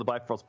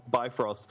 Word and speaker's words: Bifrost: I call it the Bifrost Bridge because it's spelled Bifrost. But when you look into it, it's actually pronounced Bifrost.